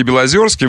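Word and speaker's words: Белозёрский: Нет, вы, да, кстати, с ударением в Белозерском, там «ё» все-таки должно быть. Потому что был князь Белосельский Белозерский, вы помните, Александр Михайлович.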